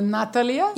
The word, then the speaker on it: Natalia: It's Natalie. You don't say Natalie, you say Natalie. How do you say it? It's like more on the Russian way, Natalia.